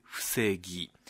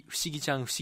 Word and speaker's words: 伏木: 木が伏せると書いて伏木、まあコミックの中ではストーリーの中ではたびたび不思議ちゃん不思議ちゃんと間違って読まれてしまう伏木さんでございますね、はい、この伏木あかりが配属されたのが伏氏の種族沖永を管理する部署です。